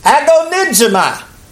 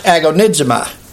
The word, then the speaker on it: agonizomai: And that Greek word for that word fight is, we pronounce it usually, agonizomai. We get our English word, I guess, agonize from that. But it really means to contend. The concordance actually calls it agonizomai, A-H-E-E, I guess is how they pronounce ahi. But I've always heard it pronounced agonizomai.